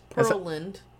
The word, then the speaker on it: Pearland: I think he said Pearland, Texas. That's, I don't know where the fuck that is. And I'm Pearland? – From... Pearland? Pearland? I don't know. – It's Pearland.